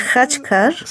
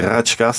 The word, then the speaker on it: Khachkar: À chaque fois, j'ai peur de l'écorcher. – C'est Khachkar. – C'est Khachkar, c'est cette stèle qui a été posée l'année dernière.